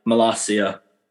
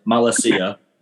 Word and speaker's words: Malacia: You've got a player that I quite like, Malacia. So everyone can stop pronouncing it, Malacia. Every fucking journo I've heard pronounces it like that.